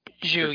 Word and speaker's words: Tju: Anyway, and how do you pronounce her last name? Brienne Ju? – Ju, yeah. – Ju, okay. Brienne Ju. Spelled T-J-U. That's why I was confused.